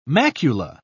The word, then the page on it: macula: British English